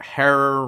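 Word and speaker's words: Herer: So, of course, we're talking about the legendary author and activist Jack Herrera, rhymes with terror.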